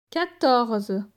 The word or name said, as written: 14